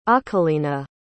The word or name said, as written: akilina